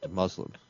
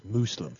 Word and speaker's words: Muslim: You don't say Muslim because you are culturally insensitive and you like to pronounce it Muslim. I, though, am a man of the world and I know the proper pronunciation is Muslim.